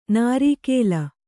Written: ನಾರೀಕೇಲ

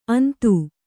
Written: ಅಂದಿ

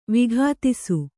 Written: ವಿಘಾತಿಸು

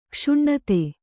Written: ಕೋಶನಾಥ

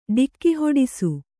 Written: ಡಿಕ್ಕಿಹೊಡಿಸು

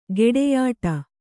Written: ಗೆಡೆಯಾಟ

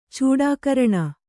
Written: ಚೂಡಾಕರಣ

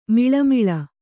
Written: ಮಳಮಳ

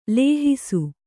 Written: ಲೇಹಿಸು

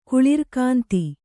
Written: ಕುಳಿರ್ಕಾಂತಿ

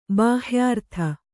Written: ಬಾಹ್ಯರತ

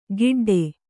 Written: ಗಿಡ್ಡೆ

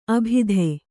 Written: ಅಬುದಿ

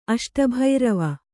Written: ಅಷ್ಟಭೈರವ

